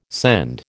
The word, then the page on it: scend: American English